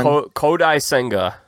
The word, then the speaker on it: Kodai Senga: Kodai Senga.